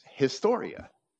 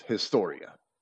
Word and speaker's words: historia: The Greek word is historia, and that's how it's pronounced, historia.